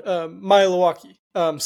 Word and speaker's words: Maealiuaki: Yeah, and there's no easy way to kind of get around Maealiuaki. So if you're walking around Stillwater, if you're saying his name right, you must be listening to our show, because that is how you pronounce it, and it's going to make a big difference.